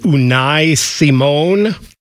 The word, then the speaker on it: Unai Simón: Now, goalkeeper, and I know I'm going to butcher his name, is it Unai Simón? – Oh, very good. Very good.